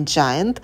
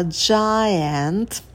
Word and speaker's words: giant: В этом слове трифтонг «ае» почетче произносим. Это не giant, а giant, да, чтобы все звуки были на месте.